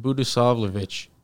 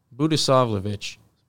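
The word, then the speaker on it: Budisavljević: So just the J sounds like a Y, and then the C is pronounced C-H. – Budisavljevic? No, sorry. Say it again. – Budisavljevic.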